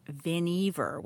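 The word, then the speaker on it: Vannevar: It's Vinever, which took me forever to discover. – I said that wrong. Yeah. – But this is like it rhymes with Beaver. That's how you remember.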